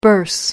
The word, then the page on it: bourse: American English